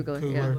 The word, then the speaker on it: Coogler: Coogler.